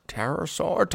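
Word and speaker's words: Terrorsaur: But the way it's spelled is terror, not terra, like a pterodactyl. – Pterosaur was spelled T-E-R-R-O-R. Pterosaur. – I thought it had a P in front of it. – No. Pterosaur, terrorize.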